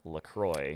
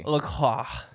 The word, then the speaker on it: LaCroix: I've already had a Coke, and drinking a LaCroix. – LaCroix. – That's not how it's pronounced. – I went for like the Northern French Canadian kind of LaCroix.